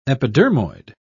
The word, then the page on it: epidermoid: British English